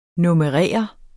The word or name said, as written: NUMMEREREDE